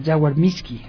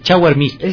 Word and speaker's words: Jaguar Miski: Bueno, ustedes ya lo conocen por sus reportajes, por sus coberturas, y en este caso, pues, él hizo este trabajo sobre el Jaguar Miski. Así se pronuncia. Bienvenido. – Gracias. Gracias a compañero Gabo también, que se encuentra por acá. Es Jaguar Miski.